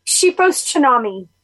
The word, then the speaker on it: Shepos Cenami: Chepos Chinami.